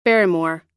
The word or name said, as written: barrymore